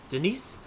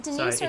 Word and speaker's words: Denis: Is it Denise or is it Denis, though? – It's I think, pretty sure it's Denis.